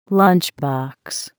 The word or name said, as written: lunch box